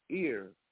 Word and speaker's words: eir: So what they suggest here in the book is to use gender-inclusive pronouns, replacing his and her with ear, E-I-R, with ear, E-I-R, pronounced to rhyme with their.